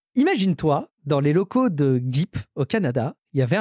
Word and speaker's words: GEEP: Ils ont prononcé tout de suite, ils ont précisé tout de suite, ça se prononce G-E-E-P, Gip. Donc, comme ça, il n'y a pas le débat, tu vois, comme GIF ou GIF, c'est bon, là, c'est Gip.